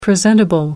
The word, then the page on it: presentable: American English